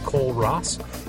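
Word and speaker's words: Kole Ross: I am at Cole Ross, KOLEROSS.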